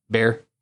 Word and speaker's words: Bexar: And I believe it's Bexar County. – Bear County. Yeah. So it looks like. – How do you pronounce that? – Bear.